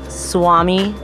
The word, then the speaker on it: Swami: It's Swami, S-W-A-M-I.